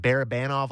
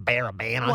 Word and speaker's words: Barabanov: You think of, was it Barabanov last year? Am I saying that right? – Yeah. – So, ish. – I sound so Canadian. Barabanov.